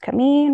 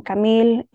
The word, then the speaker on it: Camille: So my first name, Camille, is French, pronounced Camille in French and Camille in Spanish.